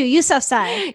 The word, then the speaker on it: Yousafzai: Is it Yousafzai? – Thank you, Yousafzai.